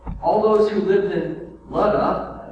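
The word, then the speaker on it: Lydda: As Peter traveled about the country, he went to visit the saints in Luda, how it's pronounced, which is crazy to me, but Luda, where he found a man named Aeneas, a paralytic, Aeneas, I think.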